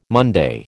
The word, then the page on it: Monday: American English